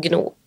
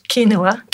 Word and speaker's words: quinoa: Det er noen poteter og gno, knoa og sånn. Gnoa, som det Hva heter det egentlig? Quinoa.